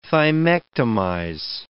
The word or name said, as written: thymectomize